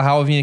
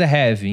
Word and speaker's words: halving: Porque a gente fala halving aqui, mas é heavy.